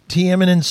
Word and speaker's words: Tiananmen: Liam, I was in China and I got a really bad stomach ache, and I was in Tiananmen Square.